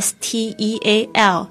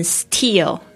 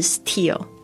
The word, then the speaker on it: steal: One can steal ideas， steal 就是指偷窃取， steal 特别注意一下这个 steal，这个 t 的声音，你会发现不是念成 steal，而是念成 steal，其实这个是英语发音的一个潜规则，你发音不好没有关系，但是我希望你可以先从听得懂开始，绝对不是 steal，是 steal。